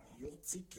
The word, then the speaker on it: Jurczyk: Geschrieben hat das Heike Jurczyk. Jurczyk, ja, das ist richtig gesagt.